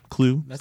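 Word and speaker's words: Kluwe: But the Minnesota Vikings kicker, Chris Klue, which I guess that's how you pronounce his last name. I'm sorry, Vikings fans, if that's wrong. It's K-L-U-W-E. Klue, sounds right? – That sounds good.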